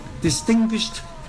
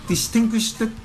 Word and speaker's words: distinguished: 咁英文嘅講法，distinguished guests， distinguished 嗱 distinguished 嗰個 sh 咧係 voiceless 嘅，sh 係冇即係冇冇聲帶冇震嘅，咁應該係讀 T 嘅，distinguished guests 咧就係 STS 啦。係，好多人會讀成咩咧？distinguished guests 錯啦。 distinguished 因為佢冇個 it 冇個 it 嗰個音噶嘛。